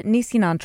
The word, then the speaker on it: Nisenan: Well, each year the Nisenan tribe, and am I pronouncing that correctly? – Yes, Nisenan. Some say Nisenan. It's a dialect shift with the S to the SH.